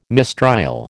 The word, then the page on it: mistrial: American English